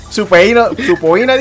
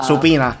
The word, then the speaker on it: subpoena: Él dice, se escribe subpoena, pero se dice supina.